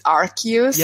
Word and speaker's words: Arceus: I mean, I guess this does confirm that the English pronunciation of Arceus is Arceus.